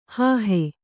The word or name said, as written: ಹೋಹು